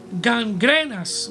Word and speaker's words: gangrenas: Y el versículo 17 dice, y sus palabras calcomerán como gran grenas, amén, o perdón, este, ¿cómo se pronuncia esto aquí? Gangrenas, mejor dicho, de los cuales son Himeneos y Fileto.